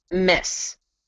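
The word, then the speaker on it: Metz: And like I said, he was drafted into the Imperial German Army, uh, deployed to, it's pronounced Miss. It's spelt M E T S, but pronounced Miss, in Lorraine to serve in the 98th Infantry Regiment.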